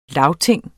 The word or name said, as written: LAGTINGENE